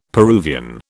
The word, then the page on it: Peruvian: British English